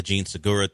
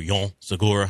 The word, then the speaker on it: Jean Segura: And yet, yeah, look, I don't mind that back and forth, the Gene Segura thing, or Jean Segura, however you want to pronounce it.